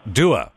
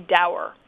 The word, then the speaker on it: dour: So, for example, the word that is spelled D-O-U-R. – Yes. – How do you say that word? – Doer. – All right. Well, here in the U.S., a whole lot of us say dower.